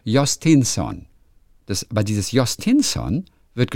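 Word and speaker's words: Jhostynxon: Ja, Y-N-X und O-N hinten. Und also ich lese es mal vor, Jostinson. – Genau, aber das wird gar nicht wie X ausgesprochen, sondern wie Jostinson.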